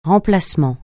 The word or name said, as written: remplacement